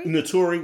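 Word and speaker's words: Naturi: Notori, which was Tasha on Power.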